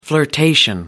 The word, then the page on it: flirtation: American English